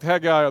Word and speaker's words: Haggai: It's spelled like Haggai of the Bible, but it's, I think he pronounces Haggai.